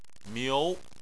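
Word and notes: miu: tone 2

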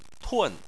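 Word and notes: tun: tone 4

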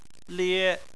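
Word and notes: lie: tone 1

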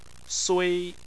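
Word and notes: sui: tone 1

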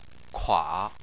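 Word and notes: kua: tone 3

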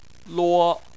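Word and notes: luo: tone 1

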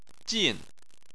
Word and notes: jin4: chinese

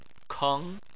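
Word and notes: keng: tone 2

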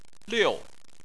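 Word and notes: liu: tone 4

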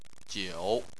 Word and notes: jiu3: chinese

